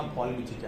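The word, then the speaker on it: palmitic: حالا در واقع این لش هم نباید تلفظ بشه ها لش، ولی خب دیگه ما میگیم پال، مثلا پالمیتیک اسید یا پال.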